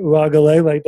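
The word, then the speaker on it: Uiagalelei: So he comes into a situation at Auburn where he can come in and learn, similar to maybe what DJ Uagalele. Did I say that right? – You got it.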